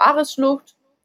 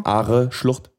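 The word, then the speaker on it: Aareschlucht: Dann waren wir bei der, also dann sind wir weiter in die Schweiz gefahren, in, zur Ares-Schlucht. Dann sind Are, wir. Are-Schlucht.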